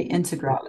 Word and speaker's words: Integral: But yeah, I mean, you have to give us the pronunciation of that route on Mont Blanc. – Into the Piotry Integral. – I'm going to write down phonetically. – It's definitely Integral. And it's from the Italian side.